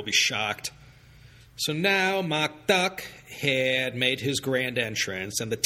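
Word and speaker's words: Duck (德): De, which ironically means morality or virtue, is pronounced Duck.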